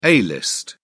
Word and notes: a-list: British English

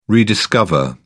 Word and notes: rediscover: British English

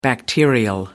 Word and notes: bacterial: American English

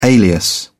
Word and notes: alias: British English

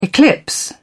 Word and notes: eclipse: British English

